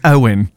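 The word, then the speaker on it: Eoghan: Echon Quig. – It's Owen. – Owen Quig. – It's just an Irish spelling.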